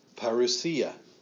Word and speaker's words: parousia: The second word I put here is parousia. Parousia. Okay, that's how it's pronounced, parousia.